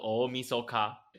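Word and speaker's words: 大晦日: 这个大会日呢，它的日文的读音叫做 omisoka，那基本上就是要除去这个前一年的这个晦气。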